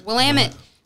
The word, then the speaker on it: Willamette: So did I already say this is from Willamette Valley? Did I say that right? – Willamette Valley, Oregon, the Van Duzer Corridor.